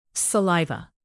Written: saliva

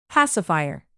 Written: pacifier

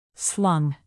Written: slung

